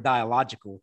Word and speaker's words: dialogical: Just feel fancy saying the word dialogical. – I know. – Am I saying it right? I don't even know if I'm saying it right. – Oh yeah, dialogical.